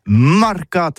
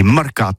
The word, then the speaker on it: marcat: Eh bien, dis ça à tous, mais le mercat, de l'Uni ou le mercat des rebelles, mais en gascogne toulousano, c'est prononcé sur les marcat avec un A. Marquette. En gascogne toulousaine, bah, du côté, par exemple, de l'île des Baches, c'est l'île Jourdain, pour parler du marché, vous prononcez les marcat.